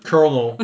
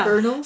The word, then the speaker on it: colonel: They didn't think, like, I can't even pronounce it. Like, I don't know where the R is in that word. – Colonel. – Yeah. – Colonel? – So it's Colonel, but I Colonel.